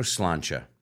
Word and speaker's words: sláinte: It's spelled C-R-A-I-C. – That's right. That's right. – Another one we said in the intro, sláinte. – Sláinte, when you have a drink. – If you have not had, a drink with an Irish person, if you've not heard sláinte yet.